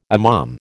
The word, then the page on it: imam: American English